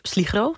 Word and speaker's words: Sligro: Naast Verstegen werk je ook, was de eerste winkel waarin je lag, was Sligro, zeg ik dat goed? – Klopt.